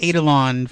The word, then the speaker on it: Eidolon: Fusus something Eidolon, FOE. – Is that how you say that? Because I've been saying it wrong. I've been saying like Eidolon for like forever. – It's Eidolon.